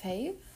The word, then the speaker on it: faith: Man sagt Faith. – Aber ich so kann dieses TH kann ich gar nicht aussprechen.